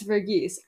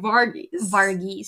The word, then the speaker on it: Verghese: And, like, we looked it up, and it's Verghese, I think. But Catherine and I were on the train for a while saying Varghese.